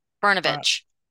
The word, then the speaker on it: Brnovich: Brnovich. – Bronovich, just Yeah. – we're missing a vowel. – Burn. Brnovich. – Brnovich.